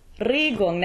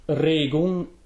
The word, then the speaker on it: Regung: Here it's the stressed syllable. – Regung, and it's very closed. – Okay, and actually, while we're there, Regung starts with an R. And in German, there's a uvular R.